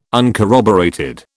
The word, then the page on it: uncorroborated: British English